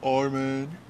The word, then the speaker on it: Armin: My name's Armin. Like Armin van Buuren, you know, the DJ Chicka Chicka. My name is exactly spelled like that.